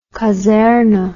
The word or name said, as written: kaserne